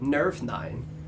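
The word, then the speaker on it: Nervnein: And the capital city for them is called Nervnine, which means gem heart. – Could you? How do you spell that? – N-E-R-V.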